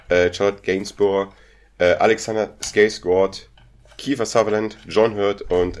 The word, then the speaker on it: Skarsgård: Skarsgård. – Okay. Das wird mit O ja. gesprochen. – Ja, ich weiß. Okay. Muss ich auch sagen, das war eine richtige Aussprache.